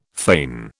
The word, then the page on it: fane: British English